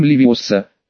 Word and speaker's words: Левиоса: Формула заклятия – Вингардиум Левиоса. Во втором слове ударение на О, и звука следует сделать долгим.